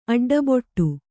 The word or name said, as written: ಅಡ್ಡಬೊಟ್ಟು